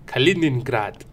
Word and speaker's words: Kaliningrad: Kaliningrad.